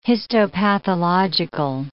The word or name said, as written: histopathological